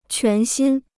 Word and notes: 全新: quán xīn

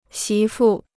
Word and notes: 媳妇: xí fù

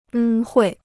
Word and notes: 恩惠: ēn huì

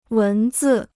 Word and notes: 文字: wén zì

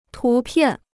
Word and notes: 图片: tú piàn

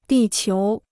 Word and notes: 地球: dì qiú

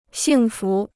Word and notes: 幸福: xìng fú

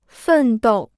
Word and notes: 奋斗: fèn dòu

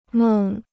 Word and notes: 梦: mèng

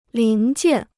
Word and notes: 零件: líng jiàn

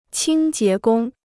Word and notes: 清洁工: qīng jié gōng

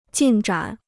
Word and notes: 进展: jìn zhǎn

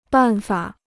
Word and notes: 办法: bàn fǎ

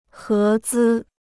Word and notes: 合资: hé zī

